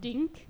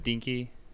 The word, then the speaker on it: Denke: D-E-N-K-E. – Yep. That's right. – Dink? – Yep. No, it's Denke.